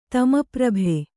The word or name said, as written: ತಮಪ್ರಭೆ